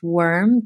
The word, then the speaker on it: Worme: I would say one of my current favorites as well, I believe it's pronounced Worm, W-O-R-M-E, Worm.